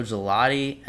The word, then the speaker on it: Gigliotti: And then the next fight, a little more exciting, Trevor Smith versus Joe Gelati, and a lot of controversy over the pronunciation of Gelati's name because it's spelled Gigliotti. – But apparently Gelati's what he wants to go by, and he's a tough motherfucker.